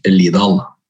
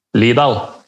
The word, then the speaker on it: Lidal: og Du, du uttaler navnet nesten korrekt. – Det er Frank Lidal. Lidal.